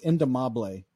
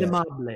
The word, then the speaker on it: indomable: It's a Spanish word. It's indomable is how you say indomable.